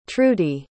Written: trudie

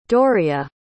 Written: dooriya

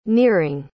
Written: nearing

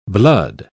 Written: blood

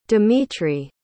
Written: demetri

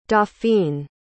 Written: dauphine

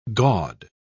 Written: gaud

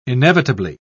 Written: inevitably

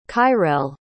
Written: kyrell

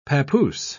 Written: papoose

